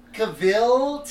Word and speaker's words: Cavill: Cavill, technically, Cavill.